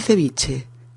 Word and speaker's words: ceviche: Por cierto, ceviche o ceviche, con V o con B, es correcto, puede escribirse de las dos maneras y se pronuncia igual, ceviche.